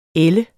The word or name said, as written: ælde